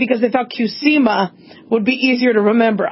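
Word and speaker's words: Qsymia: This is called Qsema. It's pronounced Qsema.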